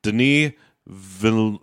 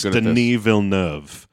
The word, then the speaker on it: Denis Villeneuve: I keep trying to learn how to pronounce his name. Denis Villeneuve. I think it's Denis Villeneuve.